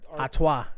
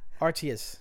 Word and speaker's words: Artois: Artois? – Yeah, that's how they say it in Belgium. Artius. – Artois. – Or fucking Stella Astoria. Nah, but it's Artois. Yeah, Stella Artois.